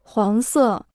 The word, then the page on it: 黄色: huáng sè